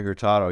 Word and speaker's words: Hurtado: Just in general with Larry Hurtado, I think, is that how you pronounce it? – Yeah, Larry Hurtado.